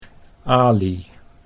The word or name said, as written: Alie